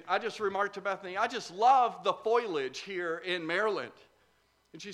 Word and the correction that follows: foliage: says to me, you mean the foliage, right? Yeah, the foliage, Bethany, the foliage. No, it's three syllables, foliage.